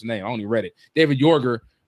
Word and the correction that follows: Jaeger: Jaeger. – There you go.